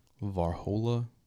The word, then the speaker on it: Varhola: Andreas Varhola Jr. Varhola, V-A-R-H-O-L-A. – Varhola? – It's V-A-R-H-O-L-A. – Oh, yes. I got no idea. – Varhola, no, something clue.